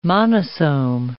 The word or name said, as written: monosome